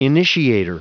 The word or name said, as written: initiator